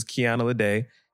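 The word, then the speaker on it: Kiana Ledé: So this is Kiana Lede. It's spelled K-I-A-N-A. L-E-D-E with the accent on top of that.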